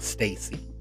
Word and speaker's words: STAYC: Number two, there's a group, a very young group called Stacy, S-T-A-Y-C. It's pronounced Stacy.